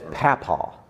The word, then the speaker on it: papaw: Is it papaw or papaw? – Oh, that is papaw. Yep.